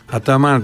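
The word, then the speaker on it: Ataman: Όλοι οι τουρκικές λέξεις τονίζονται στη λίγουσα. – Νομίζω ότι αυτό το είπε ο ίδιος. – Έχω κάνει μαθήματα τουρκικής γλώσσας. Τώρα εσείς πείτε από ό,τι θέλετε. Ναι, Αταμάν δηλαδή.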